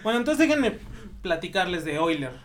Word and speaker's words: Euler: este, yo creo que muchos han escuchado de Euler o también, bueno, se pronuncia Euler, pero se escribe Euler.